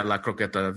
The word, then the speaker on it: La Croqueta: Yeah, La Croqueta. Very good. Very good pronunciation.